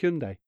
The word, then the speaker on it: Hyundai: it'll take you to High and Dry, the barber shop, or High and Dye, the place that sells paint. So you have to pronounce it Hyundai.